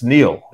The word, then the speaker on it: Niall: And so if you say Niall, you're confusing me with the well-known Egyptian river. It's Neil.